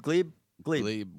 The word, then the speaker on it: Gleib: How do you pronounce the last name? Gleib. – Gleib works.